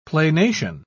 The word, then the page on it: planation: British English